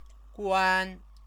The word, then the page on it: guan: chinese